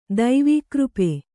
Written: ದೈವೀಕೃಪೆ

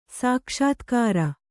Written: ಸಾಕ್ಷಾತ್ಕಾರ

